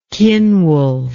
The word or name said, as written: Cynwulf